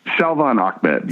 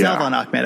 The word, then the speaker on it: Salvon Ahmed: How does that linebacking core look, and do you have the horses there to stop not just Miles Gasson, but is it Salvon Ahmed? How do you pronounce that? – Salvon Ahmed. Yeah. – Salvon Ahmed. Okay.